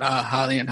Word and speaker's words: Hylian: Yeah, guys, James is a co-host of the Helion Hideout. Is it Helion or Hylian? – Could be pronounced either way, but we call it Hylian Hideout.